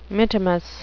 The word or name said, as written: mittimus